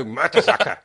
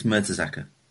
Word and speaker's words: Mertesacker: Yeah, but he won't go Mertesacker. He won't say it in a German accent. He'll just say... – Mertesacker? – Exactly. – There's a difference between pronouncing the letters right and adding an accent. – Don't you know the golden rule of German? You say it as it looks. Simple. So he's got it right. It's Mertesacker.